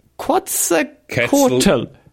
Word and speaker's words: Quetzalcoatl: Quetzalcoatl.